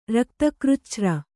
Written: ರಕ್ತಕೃಚ್ಛ್ರ

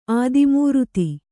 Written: ಆದಿಮೂರುತಿ

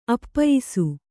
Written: ಅಪ್ಪಯಿಸು

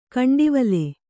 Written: ಗುಂಟವಲೆ

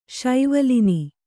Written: ಶೈವಲಿನಿ

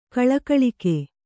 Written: ಗೊಳಗೊಳಿಕೆ

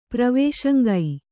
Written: ಪ್ರವೇಶಂಗೈ

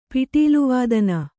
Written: ಪಿಟೀಲುವಾದನ

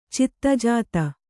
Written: ಚಿತ್ತಜಾತ

